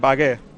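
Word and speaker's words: Wagué: Carrilero por la derecha, ¿cómo se pronuncia? ¿Bague o Agué? – Bagué. – Bagué.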